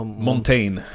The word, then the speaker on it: Montaigne: Ale na toho Montana, e, ak neviem, ako sa to vyslovuje, na toho... – Montaigne. – Na toho Montaigne by som povedal, že e, vidíš, že poruchy sústredenia existovali už aj koncom 16.